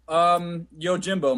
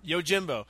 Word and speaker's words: Yojimbo: Yojimbo, which is funny because your girlfriend, when I first met her, told me that it was pronounced Yojimbo, and I said Japanese people don't, you know, have silent J's.